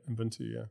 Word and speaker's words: Ubuntu: So when we think about Ubuntu, and did I say it correctly? – Yep, Ubuntu, And team philosophy.